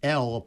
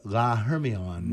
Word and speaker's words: L'Hermione: But we do not pronounce the H, and it's L'Hermione, Hermione. Hermione, okay? La Hermione, which is... – Without an H.